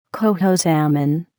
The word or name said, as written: coho salmon